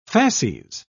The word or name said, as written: fasces